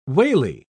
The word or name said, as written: wally